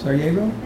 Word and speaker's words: Sarajevo: Sarajevo? – Yes.